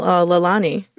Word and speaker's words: Leilani: So tell me about your latest single, Leilani. – You've pronounced it perfectly, actually. Most people get that wrong.